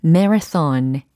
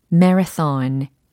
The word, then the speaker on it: marathon: 아, 마라톤이 아니라 marathon, marathon. 이렇게 발음이 되는 거고요.